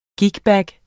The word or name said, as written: gigbag